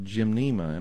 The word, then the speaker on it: gymnema: I mean, there's an herb that comes from India called gymnema, and I'll just spell it for you. – Please. – G-Y-M, just like you're going to go to the gym. – Uh-huh. – And it's just one word, but the second part is NEMA, N-E-M-A.